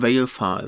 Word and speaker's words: Valefar: Er heißt irgendwie äh, Valefar oder Wallefahre oder wie auch immer man ihn aussprechen will. Ah, da ist es ja. Äh, ja, Valefar ist richtig.